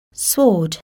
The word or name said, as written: sword